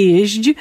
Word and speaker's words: jeźdź: Tak, rzadko się tę formę spotyka, dlatego że rzeczywiście w wymowie jest ona dosyć trudna, tutaj wymówienie tych dźwięcznych spółgłosek na końcu, nie jeźdź. Ona w takiej szybkiej wymowie się zlewa z jeść, prawda?